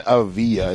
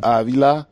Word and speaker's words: Avila: It's not even Avila. It's Avila. – Avila. You say the L? – Yeah, it's an L. It's just one. – I thought it was a silent L. – No. – That's only if there's two. – Yeah. – Oh, okay. – There's only one.